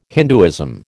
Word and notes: Hinduism: American English

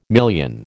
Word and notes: million: American English

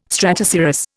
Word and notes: stratocirrus: Indian

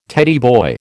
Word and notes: Teddy boy: American English

